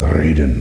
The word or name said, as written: raiden